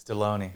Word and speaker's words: Deloney: Is your last name Deloney? – It's Deloney.